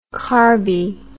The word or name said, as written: carby